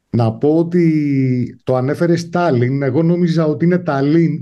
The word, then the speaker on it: Tallinn: Ο λόγος είναι ότι όλες οι λέξεις στα εσθονικά τονίζονται στην πρώτη συλλαβή. Είναι ακριβώς ό,τι βλέπεις, διαβάζεις από τα γερμανικά. Αλλά ο τονισμός είναι πάντα στην πρώτη συλλαβή, οπότε το σωστό είναι Tallinn.